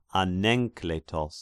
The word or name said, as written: Anenkletos